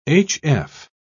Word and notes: hf: British English

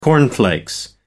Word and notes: cornflakes: American English

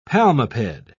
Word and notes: palmiped: British English

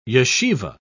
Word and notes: yeshivah: British English

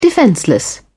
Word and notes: defenceless: British English

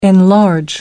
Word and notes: enlarge: American English

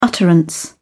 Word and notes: utterance: British English

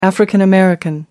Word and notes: african-american: American English

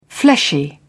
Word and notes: fleshy: British English

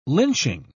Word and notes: lynching: British English

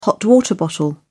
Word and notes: hot water bottle: British English

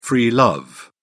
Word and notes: free love: British English

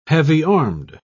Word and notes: heavy-armed: British English

